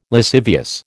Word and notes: lascivious: American English

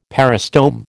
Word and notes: peristome: American English

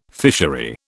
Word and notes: fishery: British English

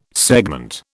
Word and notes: segment: British English

